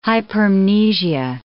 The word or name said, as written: hypermnesia